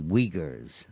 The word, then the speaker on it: Uyghur: And in China, the Uyghurs, that's spelled U-Y-G-H-E-R, but it's pronounced Uyghur, W-E-G-R, are slaves to China.